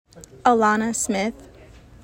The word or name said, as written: Alanna Smith